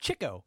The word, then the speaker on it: Chico: it's pronounced Chico. Chico, because he chased chicks, which is also very problematic.